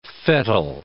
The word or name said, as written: fettle